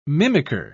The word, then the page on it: mimicker: British English